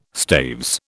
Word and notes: staves: British English

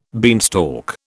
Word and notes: beanstalk: British English

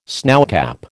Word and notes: snowcap: American English

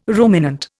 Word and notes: ruminant: Indian

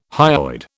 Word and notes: hyoid: British English

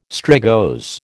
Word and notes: strigose: American English

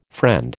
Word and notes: friend: American English

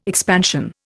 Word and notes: expansion: Indian

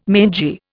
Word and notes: mangy: Indian

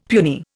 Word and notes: puny: Indian

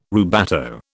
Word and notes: rubato: British English

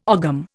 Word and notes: ogham: Indian

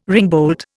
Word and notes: ringbolt: Indian

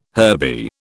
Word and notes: herby: British English

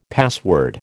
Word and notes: password: American English

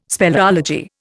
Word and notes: speleology: Indian

